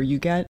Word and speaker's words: Huguette: it's spelled H-U-G-U-E-T-T-E, but I have heard it pronounced Huguette or Huguette. – Mm-hmm. – I will probably butcher it either way, but I think I'm going to go with Huguette, because that's how I heard him say it within the confines of a lecture I watched.